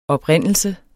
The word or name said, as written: oprindelse